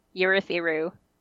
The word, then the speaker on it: Urithiru: Urithiru. – Ooh. Ooh, right. Oh, so the, the stress is on a different syllable.